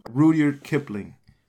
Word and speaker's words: Rudyard Kipling: Rudyard Kipling.